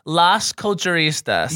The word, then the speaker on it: Las Culturistas: So, no, we have the La Canistas here. – Girl. – Oh. Got it. – Is that how you say it? – Here's the how you say it. Las Culturistas.